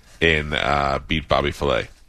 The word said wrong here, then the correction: Bobby Flay: And it's actually pronounced beat Bobby Flay.